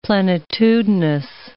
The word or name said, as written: plenitudinous